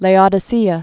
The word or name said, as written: Laodicea